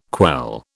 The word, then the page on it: quell: British English